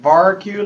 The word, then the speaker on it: VariQ: Yep, VariQ like barbecue.